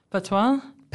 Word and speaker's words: patois: language is English, but most of the population speak produce the patois? – Patois, yeah. – Patois, yeah. Pronounced patois. – Yeah. Yeah, Jamaican patois. – Patois. Oh, Jamaican patois, sorry. – Yeah. – Jamaican pronounced patois.